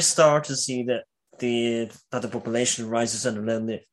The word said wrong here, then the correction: Leontius: Sorry, I'm going to try to say his name right here. Lonitus? – That's a name now. – That is he an uprising against Justinian II? – Leontius is the man who leads the big.